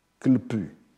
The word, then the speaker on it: klpu: et l'âme, klpu. Je prononce ça comme ça, ça s'écrit K-L-P-U.